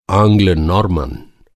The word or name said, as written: आंग्ल